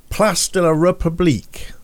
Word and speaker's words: Place de la République: Place de la République. I think I said that right. – That's perfect. I thought, that's perfect French there.